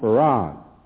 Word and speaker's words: Fard: who's called Farad. It's spelled F-A-R-D, but is pronounced F-A-R-A-D, Farad.